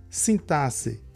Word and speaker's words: sintaxe: escrito com X, mas que se pronuncia assim mesmo, sintaxe, com o som de dois S's.